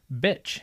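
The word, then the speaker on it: Bič: It's Czech. It's a noun meaning a whip. – A whip. – A whip? – A whip. – Whip. – It happens to be pronounced just like the word bitch.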